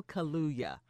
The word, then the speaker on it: Kaluuya: I, I struggle pronouncing his name, but it's Daniel Kaluuya.